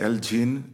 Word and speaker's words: Iljin: Como é que se pronuncia e o que é que quer dizer? – É isso, Iljin é o nome do projeto. Sim, Iljin, sim.